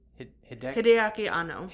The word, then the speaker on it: Hideaki Anno: How do you pronounce the creator's Hideaki Anno.